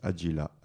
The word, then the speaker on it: Adgila: Yanis, comme ça se prononce, Yanis Adjila, A-D-G-I-L-A.